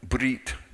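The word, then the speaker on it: brit (ברית): The covenant, brit, be. In our language, B-R-I-T, but that's kind of how you pronounce it. Or, brit, brit, which means the covenant.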